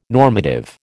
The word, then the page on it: normative: American English